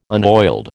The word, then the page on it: unoiled: American English